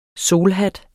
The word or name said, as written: SOLHATTE